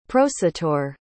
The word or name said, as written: prosateur